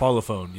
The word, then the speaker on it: Parlophone: Am I saying that right? – Parlophone, yes.